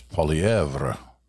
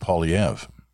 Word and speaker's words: Poilievre: And he said, if you went by the truly French version, it's Poilievre. He said, but he's very happy with Poilievre.